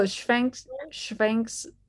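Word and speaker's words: Švankmajer: Schwenkmeyer? Is that it? Jan? – Oh, Schwenkmeyer. – With the CH, right? Is that in your pronunciation? Yeah. Schwenkmeyer.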